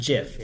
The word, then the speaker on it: GIF: It's pronounced GIF. It is pronounced...